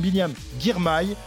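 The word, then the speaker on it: Biniam Girmay: Il nous vient d'Érythrée, se nomme Biliam Girmay, c'est comme ça qu'il faut prononcer.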